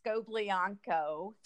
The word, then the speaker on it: Scoblionko: It's Scoblionko. – Scoblionko. – And my full name now is Scoblionko Katz, as I've remarried.